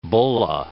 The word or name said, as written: bulla